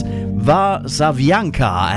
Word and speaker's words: Warszawianka: He's so good on our chat room telling me how to pronounce it. It's Warszawianka, and that is Michał Spak from Poland, his new single.